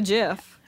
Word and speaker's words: GIF: So, but the creator, the person who invented GIFs, pronounces it JIF.